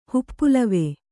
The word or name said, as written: ಹುಪ್ಪುಲವೆ